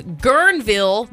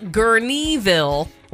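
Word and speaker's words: Guerneville: It is pronounced Gurnville, not Gurneyville."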